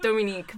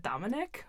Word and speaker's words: Dominique: Because it's Dominique, but like Dominique, people say, is it Dominic?